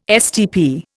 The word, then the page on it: STP: Indian